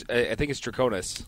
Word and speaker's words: Draconis: I pronounced this wrong last time. Is it Dracones? – I think it's Draconis. – Draconis, Overload, and Voodoo Americano Overload.